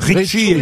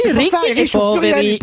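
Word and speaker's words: Ricchi e Poveri: Mais oui, Ricky et Poveri. Et si, à l'accent tonique, Poveri.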